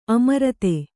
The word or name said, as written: ಅಮರ್ದು